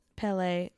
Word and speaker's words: Pele: It may be Madame Pele, the goddess of volcanoes and fire. – Oh. – Pele, it's P-E-L-E. – I think you're good.